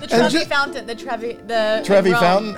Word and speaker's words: Trevi Fountain: Trevor's Fountain. – Oh. What is that? – The Trevi Fountain.